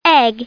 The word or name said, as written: egg